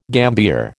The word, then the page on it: gambier: American English